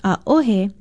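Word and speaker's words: aohe (ʻaʻohe): Most of you will remember that aole means no, and many people are confused when they hear aohe. No, it's not a mispronunciation. Aohe, spelled Okina O-H-E, and pronounced aohe, means no, too, but it is generally used to mean none.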